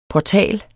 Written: portal

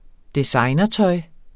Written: designertøj

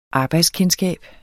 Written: arbejdskendskab

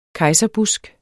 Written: KEJSERBUSK

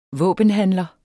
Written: våbenhandler

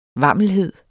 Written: vammelhed

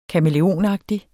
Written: kamæleonagtig

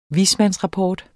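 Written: dels ... dels

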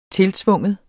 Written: tiltvinge

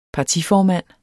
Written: PARTIFORMAND

